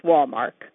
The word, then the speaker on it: Walmark: The problem with a last name like Walmark, which seems so simple and easy to pronounce, is it gets confused for other similar names like Hallmark and Walmart. But it's Walmark.